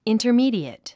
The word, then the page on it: intermediate: ìntə r míːdiət; インタァミーディエト